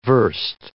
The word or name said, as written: verst